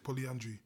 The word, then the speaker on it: polyandry: Polyagamy? – Polyandry. That's how they say it, polyandry.